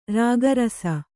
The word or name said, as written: ರಾಗರಸ